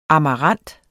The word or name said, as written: amarant